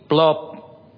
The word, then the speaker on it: Plop: You can call me Plop. Just like you throw a stone on the water, that sounds like Plop.